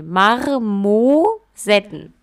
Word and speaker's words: Marmosetten: Marmo oder Marmo? Marmo, also wie Marmor. – Okay. Also mit R nach dem A. – Ja, Marmor, Mar, nee, Marmosetten.